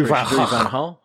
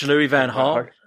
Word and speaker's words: Louis van Gaal: Come on, you've got to get your Dutch action proper. Louis van Gaal. Louis van Gaal. Louis van Gaal.